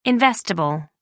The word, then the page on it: investible: American English